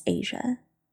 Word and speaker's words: Asia: My name is Asia, pronounced like the largest continent on the planet, Asia.